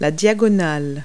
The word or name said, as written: diagonal